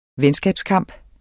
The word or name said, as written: venskabskamp